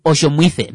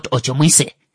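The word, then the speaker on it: Otjomuise: Let's start with, it's Oshomwithe, not Oshomwise.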